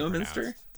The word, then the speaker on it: Leominster: Leominster is how it should be Leominster.